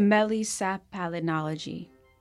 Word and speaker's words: melissopalynology: What is correct? – Melisapalinology. So it's Melisapalinology. – Exactly.